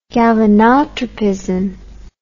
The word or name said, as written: galvanotropism